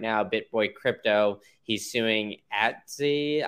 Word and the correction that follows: Atozy: I don't know if I said that correctly. It's Atozy.